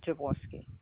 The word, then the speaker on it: Jaworski: J-A-W-O-R-S-K-I, Javorski.